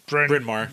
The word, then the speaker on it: Bryn Mawr: Bryn Mawr.